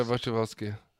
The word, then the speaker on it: Wasikowska: Mia, Wasikowska? – Yeah, Wasikowska.